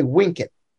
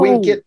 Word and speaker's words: vincit: Well, in, in Roman pronunciation, you say wink it. – The V is pronounced like a W. – Amor oh. vincit omnia. Now, if you're speaking Catholic Latin, you would say amor vincit omnia.